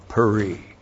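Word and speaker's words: peri (פרי): P, Shiva, R, long I, peri.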